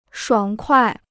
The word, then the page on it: 爽快: shuǎng kuai